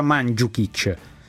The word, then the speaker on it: Mandžukić: Lui ha detto che si pronuncia Mandžukić.